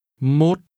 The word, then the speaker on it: một: Một. – When saying 1 or 11, the number 1 is pronounced with a low, falling, broken tone.